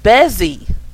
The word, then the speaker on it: Bezzy: Bezzy from Edith's.